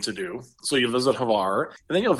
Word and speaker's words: Hvar: And the island is called Hvar. It's spelled H-V-A-R.